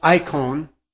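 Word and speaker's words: eikon: And I suggested to you that the word translated image is the Greek word that would be pronounced icon. We get our English word icon directly from it.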